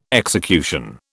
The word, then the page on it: execution: British English